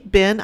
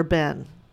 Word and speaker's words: been: You know, I was taught actually when I was in the eighth grade, but I've forgotten this until recently, that you actually pronounce the word B-E-E-N like B-I-N.